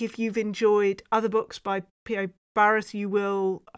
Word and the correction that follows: B.A. Paris: Did I even say that right? Let's try again. B.A. Paris. There you go.